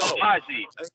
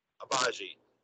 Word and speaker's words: Agbaji: And then at 13, you got Ocha Ajabi going to the Charlotte Hornets. So, Agbaji. You don't pronounce the G. – Agbaji.